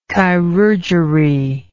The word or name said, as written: chirurgery